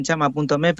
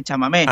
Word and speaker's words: Chamamé: muchos la llaman chama.me, pero claro. Se escribe así, fonéticamente se lee Chamamé.